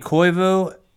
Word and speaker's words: Koivu: Yo, it's Lane Koivu, Koivu, and I'm glad to be here, Nick.